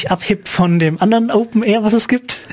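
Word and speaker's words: OpenAIRE: Das ist A-I-R-E. – Ja, aber das, ja, das, genau. Das spricht man immer noch R aus. – Das spricht man immer R aus. Okay. Aber gut. Das heißt dann Open Air.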